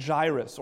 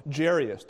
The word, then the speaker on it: Jairus: One is a synagogue official named Jairus, or Jairus, depending on how which commentator you read tells you how to pronounce it.